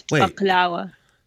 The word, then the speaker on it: baklava: You pronounce the wa? Yeah, it's baklava. – Like with a wa sound at the end.